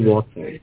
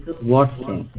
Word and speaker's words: Watson: Oh, my name is Michael Walker. – Michael Walker or Michael Walters? – Michael Walker. – I can't understand the last name. Spell that for me. – W-A-T-S-O-N.